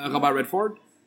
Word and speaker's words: Robert Redford: Non, mais je veux dire un acteur, par exemple, américain, par exemple, Robert Redford. Nous, on dit ça comme ça. Comment vous dites-vous? – Robert Redford. – Ah d'accord, mais au moins le Redford, vous le prononcez bien.